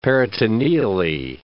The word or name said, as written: peritoneally